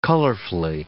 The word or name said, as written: colorfully